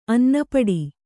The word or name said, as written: ಅನ್ನಪಡಿ